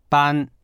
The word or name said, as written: ban1